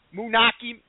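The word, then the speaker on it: Moonachie: Moonaki, New Jersey.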